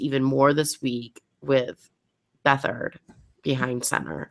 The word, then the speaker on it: Beathard: How do you pronounce his last name? Brandon's muted. – I'm sorry, I was typing. Muted. Beathard. – Beathard. Oh, Beathard.